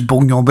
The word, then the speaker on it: Bugnon: I think it's Bougnon, but it's spelled B-U-G-N-O-N.